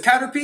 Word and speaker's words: Caterpy: So if you guys wanted to search us online, our name is Caterpy. You can find us on Google, Instagram, Facebook, TikTok. It's spelled Caterpy. Sounds like the Pokemon Caterpy, but with a Y instead of P-I-E.